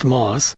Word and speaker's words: Maus: My last name is pronounced Moss. It's not spelled that way. It's spelled, M-A-U-S, which is a little different.